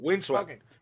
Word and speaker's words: Windswept: Windswept. – Windsweep. No sabía dónde iba la S, si en esta sílaba o la otra.